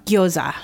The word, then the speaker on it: gyoza: Gyoza. – Yeah. – So pronunciation again, gyoza is fine or gyoza. – Gyoza. Yeah.